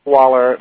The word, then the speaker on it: Schwaller: S-C-H-W-A-L-L-E-R, I believe. – Yes, R-A. R-A is usually the initials. R-A. Waller, like he said, S-C-H. – W-A-L-L-E-R.